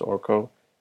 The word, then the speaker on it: Arka: You spell his name Arka, and he told me it's pronounced Orko just when you Google him and stuff.